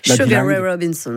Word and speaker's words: Sugar Ray Robinson: Sugar Ray Robinson.